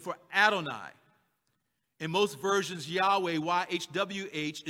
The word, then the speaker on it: Yahweh: The name Yahweh, as we pronounce it in English, consists of a sequence of four consonants, Yod, He, Wah, and He.